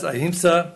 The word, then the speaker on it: ahimsa: And whereas ahimsa, did I say that right? – Yes.